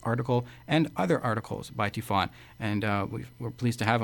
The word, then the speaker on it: Tiphaine: It's spelled T-I-P-H-A-I-N-E, kind of like Tiphaine. It's Tiphaine, T-I-P-H-A-I-N-E, Dixon, D-I-C-K-S-O-N.